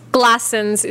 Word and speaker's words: Glassons: What's Glassons? – Glassons is the pronunciation.